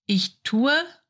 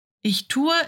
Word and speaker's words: ich tue: So, the present tense is ich tue, du tust, er es sie tut, Wir tun, ihr tut, sie tun. Ich tue is usually pronounced as ich tu. The E oftentimes is silent.